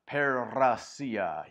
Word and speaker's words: parousia: It's parousia. You got to be able to roll your R's like a little Spanish there. Parousia.